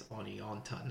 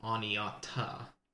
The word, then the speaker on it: Oneonta: Okay, first off, we are staying in, I think it's called Oneonta. I forgot the N. There's a second N in there that was hidden, apparently, to my eyes. And uh, so, yeah, we think it's Oneonta, not Oneata.